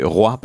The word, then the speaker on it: Roy: C'est ce qu'ont décidé de faire Suzanne Leclerc et William Roy, Roy pardon. Je vais pas le prononcer à l'américaine, mais à la française, William Roy en vous proposant un bel album autour de Freud.